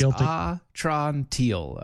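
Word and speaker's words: Atrantil: Speaking of things that you need to spell right, Atron Teal. That's Atron Teal. That's pronouncing and spelling right.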